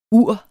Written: uret

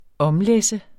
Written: omlæsse

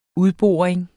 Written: udboring

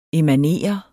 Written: emanere

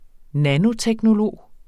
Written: nanoteknolog

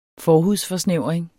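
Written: FORHUDSFORSNÆVRING

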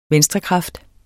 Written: venstrekraft

